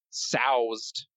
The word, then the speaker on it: soused: It's soused is the correct way to say it.